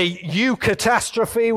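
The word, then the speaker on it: eucatastrophe: Tolkien calls a U-catastrophe, which is spelled E-U, catastrophe, but it's nothing to do with Brexit and Britain leaving the European Union.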